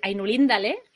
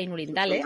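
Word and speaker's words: Ainulindalë: Ah, bueno, tú me enseñaste a decir Ainulindale, en lugar de Ainulindale, Ainulindale, y gracias a ti, ahora sé que se dice Maedros y no Maedros.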